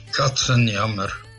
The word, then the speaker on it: Katzenjammer: Katzenjammer.